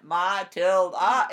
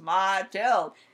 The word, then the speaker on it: Matilde: And I'm like, no, two syllables, not three. It's not Matilda, it's Matilde. And they go, oh, okay, it's Matilda.